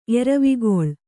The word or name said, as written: ಎರವಿಗೊಳ್